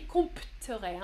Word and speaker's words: compterai: je compterai avec un P, compterai. Hein On prononce pas le P, mais je compterai l'argent.